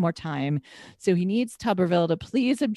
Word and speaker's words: Tuberville: which I always want to say Tuberville, but it's Tuberville of Alabama.